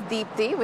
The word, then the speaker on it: Deepti: My name is Deepthi Gupta. – Ah, that's an interesting name. Could you spell it for me? – Yes. The first name is Deepthi, which is D-E-E-P-T-I. And the last name is Gupta, that's G-U-P-T-A.